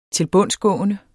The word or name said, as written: tilbundsgående